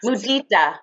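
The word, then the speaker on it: muditā: How do you say that again? Sutta? – Mudita. – Oh, mudita. Mudita. – M-U-D-I-T-A.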